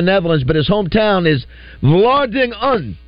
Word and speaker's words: Vlaardingen: Well, I would call it Vlaardingen. – Vlaardingen.